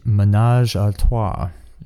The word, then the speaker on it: Ménage à Trois: I believe it's pronounced Ménage à Trois.